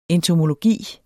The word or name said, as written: entomologi